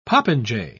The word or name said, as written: popinjay